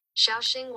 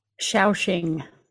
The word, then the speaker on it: Shaoxing: Shaoxing wine. – Shaoxing.